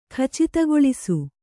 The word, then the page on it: ಖಚಿತಗೊಳಿಸು: Kannada